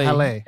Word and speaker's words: Halle: But it's Hal. I don't know if it's Hal or Halle. – H-A-L-L-E. – Yeah, Halle. – Halle it's usually Halle, but okay.